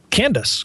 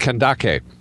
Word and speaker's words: Kandake: Candace, correct? If I'm remembering. – It was actually pronounced Kandake.